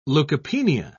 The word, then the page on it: leukopenia: British English